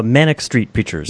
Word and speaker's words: Manic Street Preachers: Maniac Street Preachers. – Oh, Manic Street Preachers.